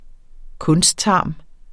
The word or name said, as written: kunsttarm